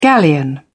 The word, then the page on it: galleon: British English